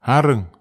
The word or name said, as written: areg